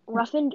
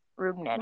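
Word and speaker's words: Rougned: Ruffin Odor. I always can't pronounce. Was that how you spell pronounce his first name? Never be able to pronounce his name. – Rubner.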